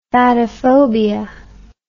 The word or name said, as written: fatophobic